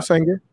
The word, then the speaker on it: Sanger: It started because, like, the woman, I don't forget, Margaret Sanger, is that what? Forgive me if I'm saying her last name wrong. – That's right, Margaret Sanger.